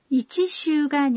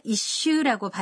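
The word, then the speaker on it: 一週: 이치슈가 아니라 이슈라고 발음하는 거군요. – 네, 발음을 정확히 들으셨네요. 그리고 시타이는 します.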